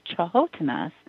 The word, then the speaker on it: Canotina: the Dakota believe Bigfoot is often with Chahotinas, which is actually spelled Canotina, but it's pronounced Chahotina.